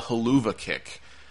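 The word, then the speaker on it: Helluva Kick: Kind of a controversial finish where Sami Zayn went for his hell of a kick. I think they call it the Huluva kick is how they pronounce it, which is kind of clever, I guess.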